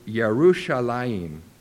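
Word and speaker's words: Yerushalayim (ירושלים): The meaning and origin of the name are obscured in history, but the name of Jerusalem, and I'm going to attempt to pronounce it here as it is in the Hebrew, I believe. It's Yerushalayim.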